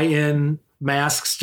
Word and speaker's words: In Masks: That's in, ma- in mask, I in masks just like spelled out phonetically yeah.